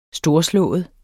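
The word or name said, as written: STORSLÅEDE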